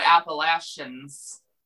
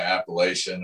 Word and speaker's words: Appalachian: I think like Appalachian or something. – Appalachian. That's how you say it. – Yeah, that's not how you said it. Appalachian.